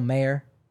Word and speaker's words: Mayer: Why am I saying Mayor? Marcelo Mayer. I was criticized for my pronunciation for him recently, so now I'm second-guessing myself. Like the hot dog, Oscar. So maybe it's Mayer. Is it Mayer or Meyer? – Oscar Meyer, but it's...